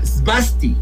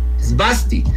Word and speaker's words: svasti: Y pues en su cultura, en el sánscrito, se pronuncia svasti, svasti, que significa bienestar.